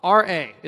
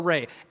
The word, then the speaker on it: Ra: Amon-Ra, R-A is the in there. You can pronounce it Ra or Re.